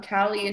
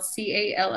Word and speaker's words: Calley: Calley is spelled Calley, and Kush, of course, Kush. I got a funky spelled name, so it's with the EY at the end.